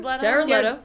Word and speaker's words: Jared Leto: Jared Leto.